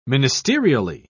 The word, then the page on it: ministerially: British English